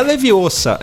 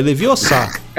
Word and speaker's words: Leviosa: Não é, levi, não é leviosa, é leviossar.